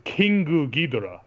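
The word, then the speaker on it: Kingu Ghidorah (キングギドラ): The idea of King, and usually untranslated, you know, it's Kingu Ghidorah is how you say it. You don't say, you know, Ghidorah-o, you know, or something. You don't say it in Japanese.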